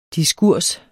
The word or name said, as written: diskurs